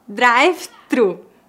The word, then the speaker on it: drive-thru: Drive-thru. Os brasileiros não pronunciam o TH, gente. Nós pronunciamos drive-thru.